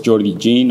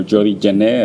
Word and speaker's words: Jané: Of course, Jordi Jean, or Jordi Jané, as his Spanish pronunciation, has yeah.